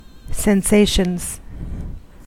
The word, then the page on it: sensations: American English